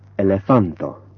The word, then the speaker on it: elefanto: Word stress in Esperanto is always on the last syllable but one. So, for example, elefanto, libera.